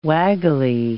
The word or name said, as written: waggly